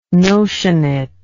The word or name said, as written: notionate